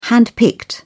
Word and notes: handpicked: British English

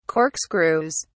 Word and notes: corkscrews: British English

